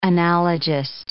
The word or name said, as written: analogist